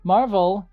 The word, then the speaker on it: Marvel: Marvel, yes, that's how it's pronounced, like the comic book and movie company, has been in this location since 1951.